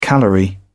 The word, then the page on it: calorie: British English